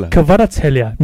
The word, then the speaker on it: Kvaratskhelia: Kvaratskhelia, yeah. I went to Google Translate. I actually chanced upon the Nepalese YouTube video, and Kavica himself actually pronounced his surname. Wow. So, his surname should be Kvaratskhelia.